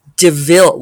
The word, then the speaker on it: de Vil: And it's wonderful to hear her like take on these different personas and like just fully lean into being Cruella de Vil, which is spelled D-E-V-I-L, devil.